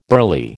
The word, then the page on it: burly: American English